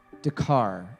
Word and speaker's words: Dakar: We're going to Dakirk, Senegal. Dakar, Senegal. I'm bad at pronunciations.